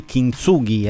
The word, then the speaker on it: Kintsugi: Tra l'altro, grazie ad Elisa, ho scoperto che questa parola giapponese si pronuncia proprio così. Kintsugi è appunto l'arte di decorare gli oggetti rotti con dell'oro nelle crepe.